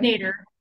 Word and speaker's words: nadir: Notes from the, I don't know how you pronounce the word, Nadir, Nader, right? – Nader.